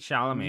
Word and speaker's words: Chalamet: Okay, Timothy Chalamet. – No, not with a T. Chalamet. – Chalamet. What's that like? – He's French. His name's French. His name's French. – Yeah. Okay. Timothy Chalamet.